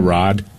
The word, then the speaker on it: Raad: R-A-A-D. What's the pronunciation of that? Rod? – Yeah, Rod.